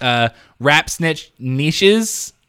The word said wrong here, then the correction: knish: perhaps, with Mr. Fantastic. – Knish. – Thank you. Knish. – It's Jewish. – Yeah, yes. Knish, yeah. That is right.